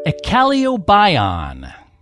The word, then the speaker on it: Eccaleobion: Echalobion. Echalobion. That is spelled E-C-C-A-L-E-O-B-I-O-N.